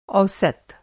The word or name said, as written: औसत